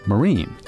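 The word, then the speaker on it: Marín: It was now spelled M-A-R-I-N, with an accent mark, which in Spanish is pronounced Marine. – And when the Americans came in, they saw that its name Marine, and they didn't notice what the accent meant in Spanish, and they just pronounced it Marin.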